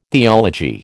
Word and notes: theology: American English

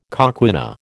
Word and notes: coquina: American English

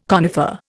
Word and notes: conifer: Indian

